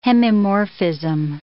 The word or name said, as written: hemimorphism